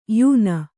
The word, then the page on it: ಯಾನ: yāna